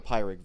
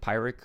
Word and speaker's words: Pyrrhic: I think I see what you're gonna say, and people don't know Pyrrhic. Is that how you pronounce it? Pyrrhic? Because it's Pyrrhus.